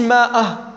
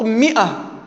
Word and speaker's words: مئة: ولهذا أنت لا تفرق بين 100 ومنه إلا بالألف، لما تقول مئة كيف تكتبها؟ بالألف تكتب ماء، لكن أثناء النطق ما تنطقش ماء، تنطق مئة،